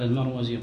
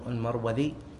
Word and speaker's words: المروذي: عندي المرودي. – المرودي؟ – ها؟ – المرودي؟ – لا لا لا، إن تنطق المرودي. لا، بالذال بالذال. لكن هي أحيانا يكتبونها المروذي وأحيانا المروزي، المروذي وأحيانا المروزي، لأن المنطقة اللي هو فيها ظاهر أن النسب إلى شرقيها، شرقي هذه المنطقة مروذ، وغربيها مروز الظهر.